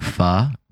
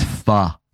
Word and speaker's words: pho: We have a line at Ate It that says, if you think pho rhymes with bro, this app's not for you. – That's amazing. Yeah, I mean, here's what I'm hoping. Okay, it's pho.